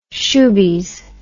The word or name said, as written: shoobies